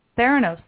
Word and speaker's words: Theranos: How do you pronounce this company? Is it Theranos? – Theranos. – Theranos, that's how you pronounce it.